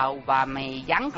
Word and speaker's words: Aubameyang: Además, para el Borussia Dortmund a Aubameyang, a ver si lo digo bien.